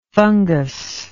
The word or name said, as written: funguslike